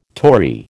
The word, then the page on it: Tory: American English